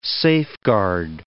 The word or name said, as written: safeguard